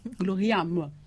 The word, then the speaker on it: gloriam: Donc, la devise de cet ordre, c'est « Ad majorem Dei Gloria ». J'ai bien prononcé ? – Oui. – Gloriam, oui. Qui signifie « Tout pour la plus grande gloire de Dieu ».